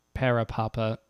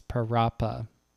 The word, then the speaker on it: Parappa: It's not Parapapa, it's Parappa. So if I've been saying that wrong this entire time, I do apologize.